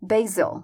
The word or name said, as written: basil